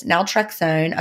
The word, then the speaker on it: naltrexone: The subject is question about naltrexone. Is that how you say it? Naltrexone? – Mm-hmm.